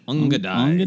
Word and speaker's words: Ongidae: How do you say Angidae? I, I, I said ungade. I was just being fancy. Ungadai. – Ungadai. Ungadai. O N G I D A E Ungadai.